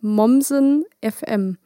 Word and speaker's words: Mommsen FM: Genau, ich wollte dich nämlich tatsächlich noch vor, ähm, bevor wir aufgenommen haben, fragen, wie es denn ausgesprochen wird. Aber du hast gerade gesagt, Mommsen FM. – Ja. – Gut, Richtig.